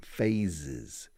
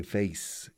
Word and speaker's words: phases: Stages, because Spanish people cannot pronounce phases correctly. Phase, they say face, y eso es cara. Face, no, phase, Zaragoza.